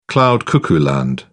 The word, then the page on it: cloud cuckoo land: British English